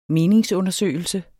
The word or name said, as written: meningsundersøgelse